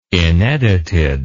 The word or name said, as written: inedited